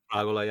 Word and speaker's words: Afragola: He was born on this day in Afragola, is that how you say it? – Yeah, Afragola, yeah.